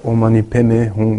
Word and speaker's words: Om Mani Padme Hum: O tibetano pronuncia Om Mani Padme Hum, mas mesma coisa.